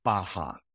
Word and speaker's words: Barra: I'm not trying to be an ass and correct you, but they have a very good pro kids program at Gracie Barra. – Ooh, my bad. – What would you call it? – Yeah. – Barra. – Barra. – Oh, okay. – So R's are pronounced H in Portuguese.